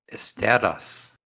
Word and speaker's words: Esteras: And it's Esteras. Is that, do you think I've pronounced that right? – Esteras.